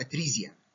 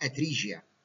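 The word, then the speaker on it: atresia: ممكن الواحد اي ليومينال ستراكشر في الجسم لو اتولد بيه مقفول تماما اسمه اتريزيا، واحيانا تنطق اتريجيا،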